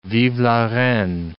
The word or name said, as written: vive la reine